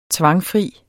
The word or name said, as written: tvangfri